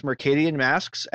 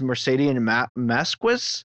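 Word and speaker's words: Mercadian Masques: Cedric LeBlanc said, I heard someone mispronounce Mercadian Masks as Mercadian Masquis. Hard to write down how he pronounced it, but it sounded awful.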